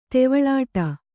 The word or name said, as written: ತೆವಳಾಟ